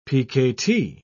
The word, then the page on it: pkt: British English